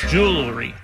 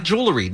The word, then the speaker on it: jewelry: Jewelry. – No, not jewelry. No, jewelry. – Jewelry. – No, not jewelry. – Jewelry. – Hold on. – Two syllables, not three.